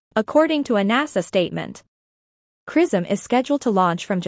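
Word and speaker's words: XRISM: The X-ray Imaging and Spectroscopy Mission, CRISM, pronounced CRISM, will analyze X-rays using the widest field of view instrument ever implemented in this kind of imaging probe.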